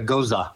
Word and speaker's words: gose: And this was a cucumber tahine goza, and it's spelled G. it's spelled G-O-S-E, but it's pronounced like a goza.